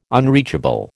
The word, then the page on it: unreachable: American English